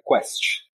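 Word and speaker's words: Quaest: A gente pronuncia Quest.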